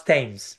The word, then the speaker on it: Thames: You pronounce Thames.